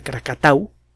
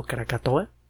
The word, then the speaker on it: Krakatau: Es un volcán que se encuentra no muy lejos de Krakatau, mal pronunciado Krakatoa,